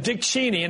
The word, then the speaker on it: Dick Cheney: Dick Cheney, and that's how you pronounce his name,